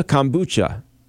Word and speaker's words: kombucha: Also, let me see if I say this right, kombucha. Okay. – Yeah. – So that is a fermented food. That's Korean, I believe, or it's definitely Asian. – Well, you know what's so confident? I want to say behind the scenes right now, Tori's over there, going, yeah, nodding her head, yes, that is correct.